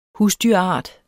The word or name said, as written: husdyrart